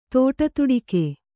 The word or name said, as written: ದುಡುದುಡಿಕೆ